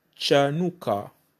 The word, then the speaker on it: Chanukkah: le mot dédicace se dit Chanuka. Chanuka s'écrit C-H-A-N-U-K-K-A-H. Chanuka, donc le K.